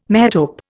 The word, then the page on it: metope: Indian